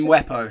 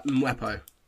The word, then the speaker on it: Mwepu: How do we pronounce it? – Mwepo. It's like Mwepo.